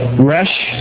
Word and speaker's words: resh: The actual pronunciation is like resh in the Hebrew, resh,